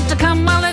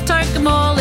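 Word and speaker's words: Tocumwal: It's not Tacumwell, it's Tokemall.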